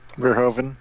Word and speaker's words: Verhoeven: Verhoeven.